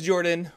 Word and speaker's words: Jordan: My name is Jordan, with a silent PH in the middle, and I am joined always by my wonderful co-host, Sir Lucian, over there at Sir Lucian Gaming.